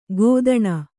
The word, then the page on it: ಕೊತ್ತಣ: kottaṇa